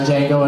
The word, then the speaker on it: Django: And Django and Jamie. No, the R is silent. – Yeah. What? No, the D is.